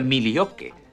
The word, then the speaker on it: Milwaukee: En realidad se pronuncia Milioque, que en lengua algonquina significa la buena tierra.